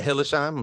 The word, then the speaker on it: Hillesheim: So for Brett Hillesheim, I'm saying that correctly again.